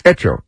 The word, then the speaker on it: Hecho: But Coca-Cola is the, how do you pronounce it? Hecho, Hecho in Mexico, how would you say that? Hecho? – Hecho. – Hecho. Hecho.